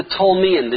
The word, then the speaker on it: Ptolemy: One empire that we're going to speak about this evening is the Ptolemyan. The general's name is Ptolemy. In English we say P-T-O-L-E-M-Y, silent P, right?